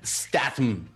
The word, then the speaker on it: Statham: lo, puse en los comentarios, se pronuncia Statham.